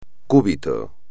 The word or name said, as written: cúbito